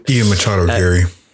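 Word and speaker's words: Ian Machado Garry: Ian Machado Gary.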